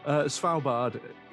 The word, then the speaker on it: Svalbard: Svalbard is actually how it's pronounced. I'm not just getting that wrong there.